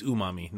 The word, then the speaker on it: umami: Like, you can have your vegan burger and a glass of wine, but you get that new mommy flavor. Yeah? Oh, yeah. I said it. Did I say it wrong? New mommy? – Well, it's umami, not new mommy.